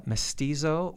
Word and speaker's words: mestizo: One of the chapters of the book addresses what you call mestizo or mestiza mysticism.